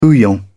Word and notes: bouillon: British English

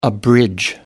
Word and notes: abridge: American English

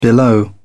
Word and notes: below: British English

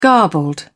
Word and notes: garbled: British English

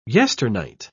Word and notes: yesternight: British English

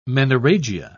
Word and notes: menorrhagia: British English

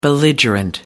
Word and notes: belligerent: American English

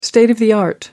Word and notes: state of the art: American English

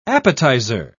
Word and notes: appetiser: British English

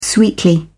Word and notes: sweetly: British English

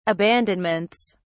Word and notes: abandonments: British English

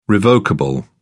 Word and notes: revocable: British English

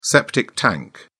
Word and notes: septic tank: British English

